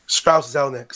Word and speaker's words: Strauss Zelnick: How do you pronounce his name again? This gentleman, Strauss Zelnick.